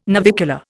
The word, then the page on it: navicular: Indian